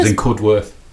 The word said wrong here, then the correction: Cudworth: Cudduth. – Cudduth. – Cudduth. – Yeah, ring bell. – We all got shouted at for saying it wrong.